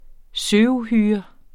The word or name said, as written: søuhyre